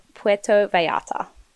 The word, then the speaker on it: Puerto Vallarta: Puerto Vallarta.